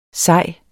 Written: sej

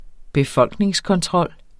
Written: befolkningskontrol

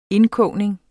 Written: indkogning

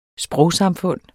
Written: sprogsamfund

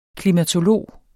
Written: klimatolog

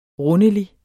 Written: rundelig

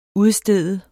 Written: UDSTEDTE

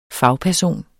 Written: fagperson

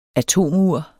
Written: atomur

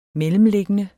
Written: mellemliggende

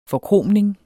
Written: forkromning

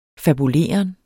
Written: fabuleren

